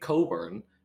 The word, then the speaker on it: Cockburn: Weems, it's up there with one of the best players in the country now, who goes to Illinois, whose name is Kofi Coburn, except his last name, Coburn, is spelled C-O-C-K-B-U-R-N. So he's Kofi Cockburn.